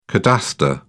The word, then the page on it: cadastre: British English